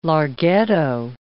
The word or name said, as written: larghetto